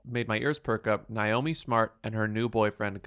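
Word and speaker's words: Niomi: It's N-I-O-M-I, Smart. – But it's still spelled Naomi. It's still pronounced Naomi.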